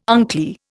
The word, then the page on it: unclear: Indian